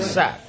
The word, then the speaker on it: sac: And it's even spelled like the French, you know, sack. S-A-C, sack.